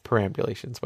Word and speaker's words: perambulations: I might have said it wrong. It's perambulations, whatever.